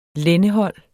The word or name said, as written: lændehold